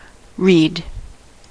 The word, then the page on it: read: American English